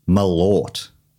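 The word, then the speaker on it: Malört: So Jeff's question is, what is malort? What is malort? – How do you spell that? – M-A-L-O with an umlaut, R-T.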